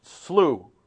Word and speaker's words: Slough: Chris and I, my wife and I, were out at SLU. That's the pronunciation we learned. S-L-O-U-G-H.